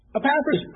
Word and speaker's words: Epaphras: Sorry. I never pronounced it the other way that Mark did. But Epaphras. Epaphras came and he taught.